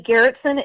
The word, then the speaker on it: Gerritson: It's a public figure page, Becky Gerritsen, and my last name is weird. It's spelled G-E-R-R-I-T-S-O-N.